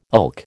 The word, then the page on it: ilk: American English